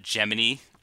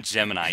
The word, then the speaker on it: Gemini: going also back to the Gemini. Excuse me, it's not Gemini, it's Gemini. – Okay. – Yeah, that's a little piece of trivial. Actually, yeah. It's pronounced Gemini, according to the NASA scientists.